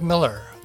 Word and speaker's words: Millar: Ross MacDonald is the main pseudonym for the writer Kenneth Millar, pronounced like Miller.